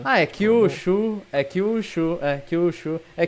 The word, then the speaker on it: Kyushu: Como é que se pronuncia? Kyushu? Kyushu, é, eu falaria Kyushu.